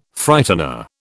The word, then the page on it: Frightener: British English